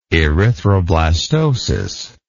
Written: erythroblastotic

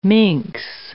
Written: minx

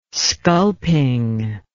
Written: sculping

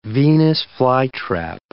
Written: Venus flytrap

